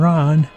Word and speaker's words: Rahn: That's right. Darren Ron. R-A-H-N. The A is silent.